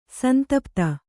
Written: ಸಂತಪ್ತ

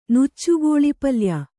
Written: ನುಚ್ಚುಗೋಳಿಪಲ್ಯ

